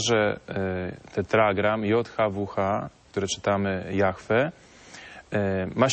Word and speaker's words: Jahwe: Widzimy tetragram u góry, po lewej stronie, JHWH, które czytamy jako w Piśmie Świętym Jahwe, tak się właściwie je wymawia, i pod spodem Haya, czyli czasownik być.